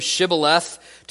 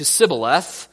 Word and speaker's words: Shibboleth: It goes from Shibboleth to Sibboleth, and we're not really sure. Maybe there was a lisp with one of them.